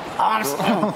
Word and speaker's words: Armstrong: Louis Armstrong.